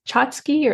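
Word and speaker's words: tchotchke: Is that how they say it? Chotsky or? – Yep.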